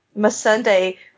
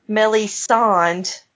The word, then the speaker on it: Missandei: Brienne, Asha, Missandei, probably the most racially offensive, Missandei, which he pronounces Melisande, and Dany in the later books, and heaven help us when he does sex scenes.